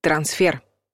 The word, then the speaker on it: трансфер: По нормам русского языка ударение падает на последний слог — трансфер.